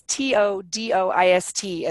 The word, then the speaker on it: Todoist: It's Todoist, as if it's one big word.